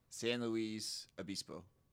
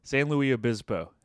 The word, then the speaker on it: San Luis Obispo: San Luis Obispo. – Oh, you did pretty good. Pretty good. San Luis Obispo.